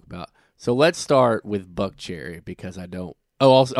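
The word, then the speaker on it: Buckcherry: This week we are discussing Buck Cherry. Did I say it right? – Yeah. – Okay, good. I was saying it wrong this week, so...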